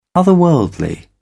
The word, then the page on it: otherworldly: British English